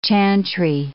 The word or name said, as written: chantry